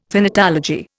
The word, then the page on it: thanatology: Indian